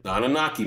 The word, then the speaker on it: Anunnaki: Anunnaki, man. You guys look that up. You can find out a lot of information about Anunnaki. It talks about our beginnings as our origin. The Anunnaki are in the Bible.